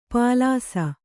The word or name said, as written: ಪಾಲಾಸ